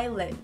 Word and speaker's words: island: Tira esse S daí e fale somente island, ok? – Your island.